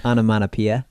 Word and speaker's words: onomatopoeia: Onomatopoeia.